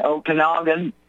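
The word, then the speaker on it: Okanogan: And it's pronounced Chehalis and Okanagan, and I forgot what you said the third one was.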